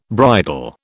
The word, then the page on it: bridal: British English